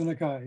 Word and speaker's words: Nakai: Arthur Nakai. – Right. Arthur Nakai.